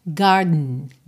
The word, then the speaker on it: garden: Esse E da palavra garden não é uma vogal muito pronunciada, você não diz garden, garden. É só uma ligação mesmo, garden, garden, que às vezes também tem essa puxadinha nasal para dentro.